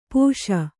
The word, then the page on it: ಪಾಶಿ: Kannada